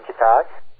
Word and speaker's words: Kitai: Ladies and gentlemen, Lynn Kitai. I am pronouncing your right last name correctly, or am I not? Correct? – Like a key and a tie. Key tie.